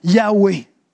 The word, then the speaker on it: Yahweh: Et donc, communément, ce qu'on reconnaît, c'est qu'il devait le prononcer comme ça, Yahweh.